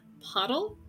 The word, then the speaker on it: Pottle: Pigeon, Hikari, and Pottle? – Yep. – Hotel. It rhymes with hotel, like hotel. – Does it? I totally remember that now. – It rhymes with scrotal.